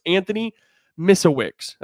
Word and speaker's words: Misiewicz: So it's Anthony Misiewicz. That's the best way I, have heard it pronounced.